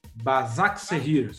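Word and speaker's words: Başakşehir: Como que eu vou pronunciar isso aqui, meu Bazak Serrir. – Bazak Serrir.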